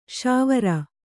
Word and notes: ಶಾವೀರ: śavīra

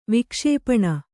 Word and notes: ವಿಕ್ಷೇಪಣಿ: vikṣēpaṇi

